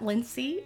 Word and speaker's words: Lincee: For those of you who are new, my name is spelled weird. It's L I N C E E. I go by Lindsay, Lindsay, Lacey, Leslie, Lucy, Slinky, Slink, just name it, I'll answer.